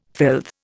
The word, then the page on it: filth: Indian